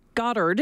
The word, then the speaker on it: Goddard: Dr. Ellen Goddard is a professor of agriculture marketing and business at the University of Alberta.